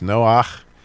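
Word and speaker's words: Noach (נח): It, obviously, you know, it's Noach if you pronounce that in Hebrew.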